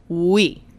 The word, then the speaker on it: Oui: We. We. – Spell it W-E? – O-U-I.